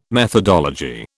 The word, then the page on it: methodology: British English